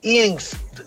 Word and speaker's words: INXS: Mira, Fernando, hay un grupo que se llama INX. Se escribe INXS.